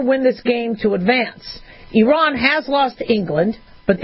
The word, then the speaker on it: Iran: Iran is asking that we pronounce it correctly.